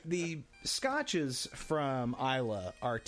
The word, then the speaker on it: Islay: It's spelled I-S-L-A-Y, which I always used to think was Islay. But it's actually pronounced Isla, according to Wikipedia. – Okay. – And, you know, Wikipedia is always right. – So it's like island without the and.